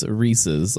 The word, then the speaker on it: Reese's: We've already established that it's Reese's. Like, there is a guy, and his name is Reese, and this is his candy that he came up with, and he decided to put it into pieces and cups and things like that. So Reese's Pieces, it rhymes, it makes sense.